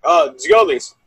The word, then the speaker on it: Giannoulis: Giannoulis, 5.8. You could pronounce that one there. – Giannoulis. Yeah, the TZ? – Giolis. – Giolis, thank you.